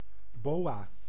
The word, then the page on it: boa: France; [bo.a]